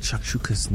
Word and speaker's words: shakshuka: Yeah, we're getting into this thing called shakshukas now. Am I saying it right? – Shakshuka. – Yeah. Am I saying it right? – Yeah. Yes.